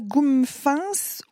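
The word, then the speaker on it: Gumefens: On prononce ça Goumfance ou Goumfance ou Goumfan. Voilà.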